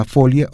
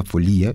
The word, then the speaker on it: folía: Ojo con la pronunciación, hay personas que dicen que es la folia, otros dicen la folía, y esto depende mucho de quién lo esté pronunciando,